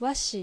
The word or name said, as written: Washi